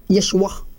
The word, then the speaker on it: Yeshua: Dapat yung pangalan ng Diyos, sabi niya. Kwan lang. Yeshua. Sabi niya, hindi mo pwedeng i-pronounce yan sa ibang kwan. Hindi mo pwedeng i-pronounce ng ng kung ano man.